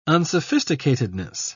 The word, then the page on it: unsophisticatedness: British English